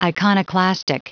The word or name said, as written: iconoclastic